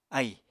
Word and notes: ahì: Apatani

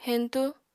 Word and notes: heñtú: Apatani

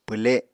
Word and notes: pwle: Apatani

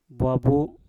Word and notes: bwabu: Mpur

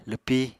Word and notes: lwpi: apatani

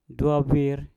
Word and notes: dwau bwir: Mpur

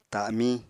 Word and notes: ta7miñ: apatani

